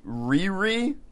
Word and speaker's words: Riri: Anyway, the other Iron Man is a 15-year-old girl named, and I think I'm pronouncing this correctly, Riri? – That is the pronunciation I've heard.